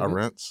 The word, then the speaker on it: Arendt: Arendt.